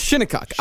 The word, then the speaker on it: Shinnecock: It's not Shinnecock. – It's Shinnecock. – I looked it up. I looked it up, and I even heard an actual pronunciation, Shinnecock.